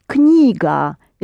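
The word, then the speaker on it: Knyga (книга): But for some reason you don't pronounce the K, and we do. Knyga is something that you put on your knee and read.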